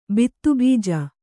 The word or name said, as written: ಬಿತ್ತುಬೀಜ